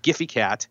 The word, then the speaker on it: Gfycat: And it's spelled G-F-Y-C-A-T. So Giphy Cat.